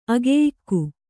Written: ಅಗೆಯಿಕ್ಕು

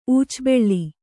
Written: ಊಚಬೆಳ್ಳಿ

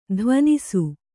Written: ಧ್ವನಿಸು

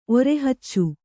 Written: ಒರೆಹಚ್ಚು

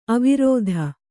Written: ಅವಿರೋಧ

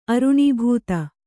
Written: ಅರುಣೀಭೂತ

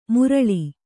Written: ಮುರಳಿ

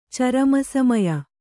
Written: ಚರಮಸಮಯ

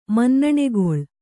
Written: ಮನ್ನಣೆಗೊಳ್

